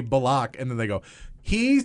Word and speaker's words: Bullock: Yes, it's Bullock. It is not Bullock. It is Bullock.